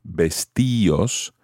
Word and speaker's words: vestíos: However, you have to add an accent to the I in order to keep that type of pronunciation. Vestíos, vestíos. This is because the combination of I-O normally is pronounced as one syllable. Think of the word Dios, Dios. It's not Dios, but Dios. So vestíos requires an accent.